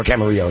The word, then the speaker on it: Camarillo: Come on out, check it out in Ventura Harley-Davidson, just off the 101 at Central in Camarillo, or Camarillo is how it's pronounced, or stop by Simi Valley Harley-Davidson, just off the 118 at Princeton in Moore Park.